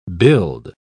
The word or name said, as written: build